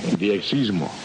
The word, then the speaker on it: diexismo: Quedamos la promesa de que ellos vendrían a hablar un poquito más sobre la afición de la radio y de la diexis, ¿eh? Se dice así. – Diexismo. – Mejor dicho.